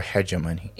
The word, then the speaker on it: hegemony: Is it hegemony or hegemony? – It is hegemony.